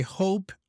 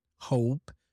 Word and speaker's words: hope: And I hope, I really hope, by the way, the way you said hope, you have to make sure that you pronounce the diphthong, O, hope, because if you say hop, it sounds like another word in English.